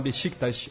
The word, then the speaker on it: Beşiktaş: Ah, acho que só uma, uma ressalva bem pequenininha para ajudar na pronúncia do Beşiktaş. Tá? É só falar o S, esse S cedilha do turco, é como se fosse um S carioca com bastante sotaque.